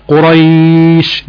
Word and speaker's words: قريش: تمام، زي مثلا زي كلمة نوم، زي كلمة صيف، زي كلمة خوف، الكلمات دي كلها حكم الواو دي واو لين، قريش الياء دي ياء لين، بينطقها ازاي بقى؟ بينطقها في حالة الوقف قريش بتمد، في حالة الوقف بتمد.